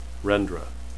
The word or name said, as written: rendra